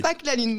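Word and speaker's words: PaQ'la Lune: Pâques la Lune. Donc, l'association Pâques, ça s'écrit P-A-Q. Et là, il faut faire attention parce que donc, il y a un P majuscule, un A minuscule, un Q majuscule, apostrophe, la, L-A, et plus loin, lune, L avec L majuscule, U-N-E, et plus loin, Bretagne avec un B majuscule.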